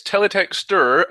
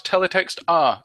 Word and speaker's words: teletexter: It's teletexter and not teletext-ah.